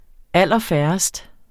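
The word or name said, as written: allerfærrest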